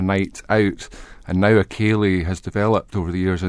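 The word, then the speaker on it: Ceilidh: I mean, Cayleyan is a Gaelic word, which is about 20,000 letters that you don't pronounce. It's just Cayley.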